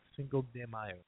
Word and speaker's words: Cinco de Mayo: Am I de. – De. – Cinco de Mayo. – Cinco de Mayo. There you go. – Even though it's spelled D E.